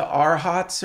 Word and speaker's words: Arhat: And this, and he talks to the arhats or whatever.